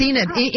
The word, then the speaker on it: Enid: And it's Enid. Frank, it's Enid. I'm going to spell it for you. E-N-I-D.